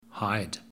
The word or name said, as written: Hyde